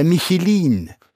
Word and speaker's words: Michelin: Guide Michelin? Habe ich es richtig gesagt? – Die Deutschen sagen, der Michelin. – Oder wie der Deutsche sagt, der Michelin.